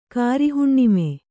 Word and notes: ಗೌರಿಹುಣ್ಣಿಮೆ: Kannada

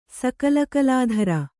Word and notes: ಸಕಲಕಲಾಧರೆ: sakala kalādhare

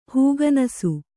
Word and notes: ಹೂಗನಸು: Kannada

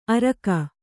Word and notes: ಅರಕ: kannada

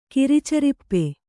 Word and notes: ಕಿರಿಚರಿಪ್ಪೆ: Kannada

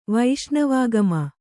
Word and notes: ವೈಷ್ಣವಾಗಮ: kannada

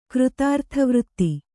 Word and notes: ಕೃತಾರ್ಥವೃತ್ತಿ: Kannada

